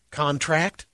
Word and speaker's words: contract: When a word has multiple meanings, in most cases, the noun form stresses the first syllable and the verb stresses the last. For an example, contract.